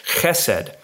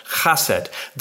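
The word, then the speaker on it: chesed (חסד): Okay, the word here translated as steadfast love is chesed, sometimes pronounced chased.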